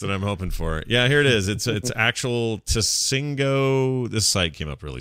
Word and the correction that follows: Tsingtao: No, it's Tsingtao. Tsingtao. – Is that how you say it? Tsingtao? – Tsingtao. I think that's the pronunciation.